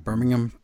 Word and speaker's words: Birmingham: Birmingham. Birmingham. It's Birmingham here. Over in England, it's Birmingham.